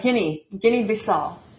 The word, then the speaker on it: Guinea-Bissau: Oh, that's Guinea-Bissau.